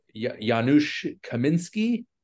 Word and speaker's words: Janusz Kaminski: But I also want to mention Janusz Kaminski.